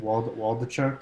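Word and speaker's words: Waldichuk: Is it Waldachowski who came over from New York? Am I pronouncing his name correctly? – Waldachuk. – Yeah, Waldachuk.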